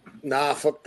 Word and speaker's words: Norfolk: Norfolk, that's how they say it. Yeah, they say Norfolk.